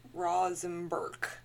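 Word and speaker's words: Rožmberk: The Czech spelling of it is R-O-Z-M-B-E-R-K. So Rosenberg is phonetically how you would pronounce that.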